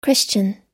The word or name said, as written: christian